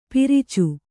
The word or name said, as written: ಪಿರಿಚು